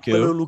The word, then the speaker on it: Romelu: Romelu Lukaku. – Romelu Lukaku.